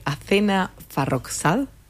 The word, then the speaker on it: Athena Farrokhzad: Hablando de estas traducciones, hace algunos años hiciste la de Blanco de Blanco, de la poeta y dramaturga sueca, lo voy a pronunciar mal, vos vas a pasar a hacerlo correcto, Athena Farrokhzad. – No, pronunciaste perfecto.